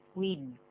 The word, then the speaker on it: We'd: We'd better leave now. – We'd 本来那个的这样发音时把它停住。